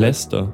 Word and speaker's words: Leicester: Wie spricht man die Stadt aus? Die Universitätsstadt? – Leicester. Das ist Leicester City. – Und man schreibt es Leicester. – Ja. Viele sagen auch Leicester, aber eigentlich ist der Fußballverein unter dem Namen Leicester City bekannt.